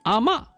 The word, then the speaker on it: 阿嬷: 所以最终呢这个魔，我再跟大家说一下这个字典确实只有这一个读音但是呢当地人潮汕人这个字呢他们要读骂阿骂。